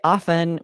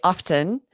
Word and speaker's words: often: Yes, I say often with a silent T. I have noticed you say often.